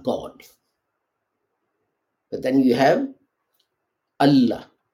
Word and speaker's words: Allah: So you'll have you'll say al illa. You wouldn't say Allah. The fact that you say Allah means that it's not a combination of al and illa.